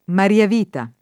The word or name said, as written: mariavita